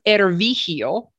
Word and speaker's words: Ervig: I'm pronouncing it Ervig.